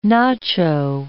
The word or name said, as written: nacho